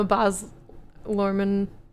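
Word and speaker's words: Baz Luhrmann: Oh, I'm a Boz Lorman. I think that's how you say his name. Yeah.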